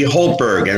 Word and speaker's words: Houltberg: Susie Holtberg.